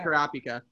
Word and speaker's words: Kurapika: To this day, I think Kurapika is that how you pronounce it? – Yeah, Kurapika.